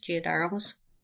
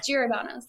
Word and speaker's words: Giordano's: I think it's pronounced Giardarnos, Giordano's. Oh from, yeah, Giordano's.